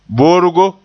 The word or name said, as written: borgo